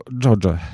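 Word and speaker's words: Xoxe: Pisze się przez dwa... – No właśnie, X-y. Problem w tym, że się Albanii był, nie pisze. – Tak, sprawdzałem to. Czyta się Dżodże. Aha. Dżodze albo Dżodże.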